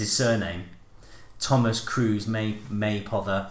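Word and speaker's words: Mapother: The name Maypother is pronounced like May Bother, only with a P.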